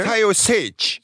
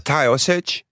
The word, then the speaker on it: Taoiseach: Taoiseach. Taoiseach. – Hoe spreek je dat echt zo uit? – Ja, Taoiseach. – Taoiseach.